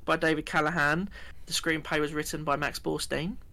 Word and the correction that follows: Borenstein: Borenstein, sorry.